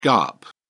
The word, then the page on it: gaap: British English